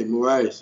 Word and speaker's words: Moraes: He took out Frankie Edgar, take out Moraes. Did I say that right? – Yeah, Moraes.